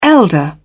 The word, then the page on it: elder: British English